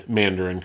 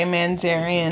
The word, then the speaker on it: Mandarin: where you refuse to pronounce the word Mandarin correctly. – And because, I still say Mandarian.